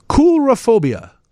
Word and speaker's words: coulrophobia: I will have to pronounce it. Coulrophobia. But it's spelled C-O-U-L-R-O-P-H-O-B-I-A. And it is pronounced coulrophobia.